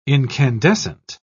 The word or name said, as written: incandescent